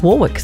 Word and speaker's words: Warwick: I'm congratulating him on the pronunciation of Dionne's surname, which most people want to say Warwick, but she says Warwick, so That's right.